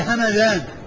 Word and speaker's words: همذان: همذان، مدينة من المدن اسمها همذان بالميم المفتوحة والذال المعجمة.